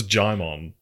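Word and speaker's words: Djimon: Digimon Honsu, Regina Hall, Nicki Minaj. – Digimon. – Is that not how you say it? – No, it's Jaimon.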